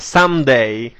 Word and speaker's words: Someday: E il titolo della canzone sono i cantanti Somedai. – Innanzitutto, sindachessa, Someday. – E appunto, io, ecco, lei mi sottolinea la perfetta pronuncia, io Somedai.